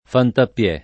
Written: Fantappiè

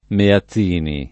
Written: Meazzini

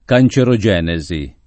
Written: cancerogenesi

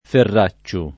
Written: Ferracciu

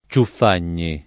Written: Ciuffagni

